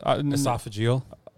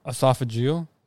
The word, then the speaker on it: esophageal: How do you say that? – Esophageal. – Esophageal? – Yeah.